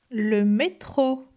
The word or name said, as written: Le metro